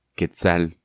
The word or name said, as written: Quetzal